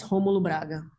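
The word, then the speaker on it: Rômulo Braga: How do you pronounce his last name? Is it just Romulo Braga? – Yes, Romulo Braga.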